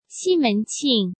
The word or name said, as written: Ximen Qing